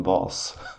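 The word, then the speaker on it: Vaas: Vas i... bättre... Far Cry 3. – Uttalas hans namn verkligen Vas? – Vas, ja.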